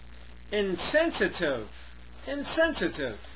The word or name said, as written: insensitive